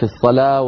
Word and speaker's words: الصلاة: أحيانا تكتب بحرف وتقرأ بحرف كما ذكرنا في الصلاة والزكاة والحياة والنجاة، كتبت بالواو وتنطق بالألف، أيضا بوبوها.